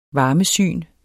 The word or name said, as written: varmesyn